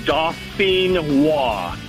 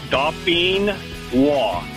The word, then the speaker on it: Dauphinois: It's, like I said, it's French. It's Dauphinois. And Dauphinois.